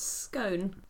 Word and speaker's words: scone: How do you pronounce scone? Scone. rhymes with bone, rhymes with gone.